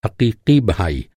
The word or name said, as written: हक़ीक़ी